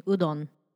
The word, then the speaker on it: udon: Also, pronunciation, udon.